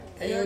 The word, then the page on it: yo: Ansus